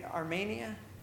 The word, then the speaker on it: Armenia: And Armenia, did I say that right? – Yes, sir.